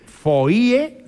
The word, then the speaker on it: foie: Foie, que es una palabra francesa que a mí siempre me ha llamado mucho la atención porque se escribe foie. Sí. Y se pronuncia foie. Fíjate, la O, la I y la E se escribe. Y luego se pronuncia con la U.